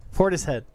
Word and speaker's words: Portishead: It's always Porterhead. Is it? Am I saying it wrong? – You're saying it wrong. – Porterhead? Portishead. Porterhead. I'm sorry.